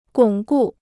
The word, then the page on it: 巩固: gǒng gù